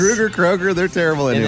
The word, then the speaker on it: Kroeger: Chad Kroeger, I believe, is how you say his name now, remember? – Oh, no, it's Kroeger. – We had it on the show. It's Chad Kroeger.